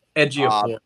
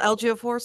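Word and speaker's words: Ejiofor: Edgeophore, I think is how you say it. – Okay. It's like Atiel Edgeophore, something like that.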